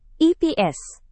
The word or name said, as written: ips